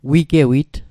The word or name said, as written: wigewit